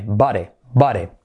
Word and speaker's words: body: Curiosidad, la D como R suave, letra semiclave y la I como E. Body. No confundir con amiguete. What's up, body? Que eso se deletrea distinto, que es B-U-D-D-Y.